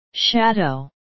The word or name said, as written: shadow